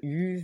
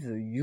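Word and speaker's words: HU: Vous venez d'écouter en avant-première dans l'émission le groupe U, The U ou The Q. Tout dépend si vous êtes mongol, anglais ou français. Ça se prononce de différentes manières. Par contre, ça s'écrit H-U.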